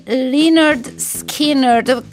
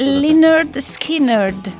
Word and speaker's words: Lynyrd Skynyrd: Come si pronunciano esattamente? – Bravissima. – Era giusto? – È giusto. Lynyrd Skynyrd è un nome che se non si è mai sentito pronunciare da un americano, io stesso l'ho sbagliato più volte perché da Lynyrd Skynyrd a... – Sì. – Non so cosa, però la pronuncia giusta è quella che hai detto tu adesso.